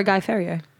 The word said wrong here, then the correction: Fieri: Guy Fieri. – I always say it wrong.